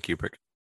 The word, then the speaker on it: Kubrick: You are Stanley Kubrick.